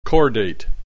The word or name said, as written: chordate